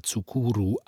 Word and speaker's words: Tsukuru: Sein Vorname Tsukuru wurde mit einem chinesischen Zeichen geschrieben, das man unter Umständen auch Saku aussprechen konnte. Doch solange es sich nicht um offizielle Dokumente handelte, verwendete er die japanische Silbenschrift, aus der die Aussprache Tsukuru eindeutig hervorging.